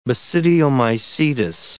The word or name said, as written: basidiomycetous